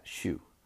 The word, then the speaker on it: Xu: Anyways, CEO Tony Hsu, XU, that's how you pronounce that, Hsu, said it plans to introduce greater transparency about wage breakdowns, but the pay model will remain the same.